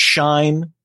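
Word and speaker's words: Shein: And I thought it was going to be, oh, maybe this will be the next Shine, as I now know it's pronounced.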